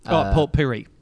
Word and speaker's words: Port Pirie: But then there's a town, I'm not sure how to pronounce this, north. It's called Port Pirie, maybe? – Port Pirie. – Yeah, Port Pirie.